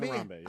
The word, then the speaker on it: Harambee: Oh, it's Harambee? It's not Harambee? – It's Harambee, yeah.